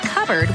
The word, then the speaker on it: cupboard: When we come back, why it's pronounced covered when it's spelled cupboard?